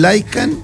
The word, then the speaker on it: Lykan: La ahí marca se escribe, amigo, para que ustedes sepan, lo busquen, se escribe LYKAN.